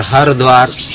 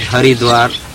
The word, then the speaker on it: Haridwar: If you are a devotee of Hari, you would like to call it Haridwar. If you are worshipper of Shiva, you would call it Hardwar. This town is pronounced two ways.